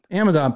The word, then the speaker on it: Amadon: Amadon.